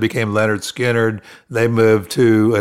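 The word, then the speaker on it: Lynyrd Skynyrd: And pronounced Leonard Skinner.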